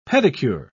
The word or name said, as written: pedicure